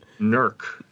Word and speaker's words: Newark: I'm from the one that's pronounced NERC. Oh. NERC, Ohio. Newark, Ohio. NERC, Ohio, they call it.